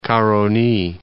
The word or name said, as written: Caroni